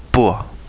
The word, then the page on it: pua: kapingamarangi